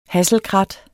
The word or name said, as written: hasselkrat